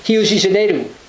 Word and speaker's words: Rio de Janeiro: but in Portuguese it's actually pronounced Rio de Janeiro, because their first R is pronounced like an H, D can have a J sound, and O's at the end of the word in Portuguese have an U sound, a U sound.